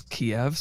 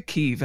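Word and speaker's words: Kyiv: I actually just learned, we were talking about before we started recording, I did not know how to pronounce the capital of Ukraine. I always thought it was Kiev. So it's actually Kiev.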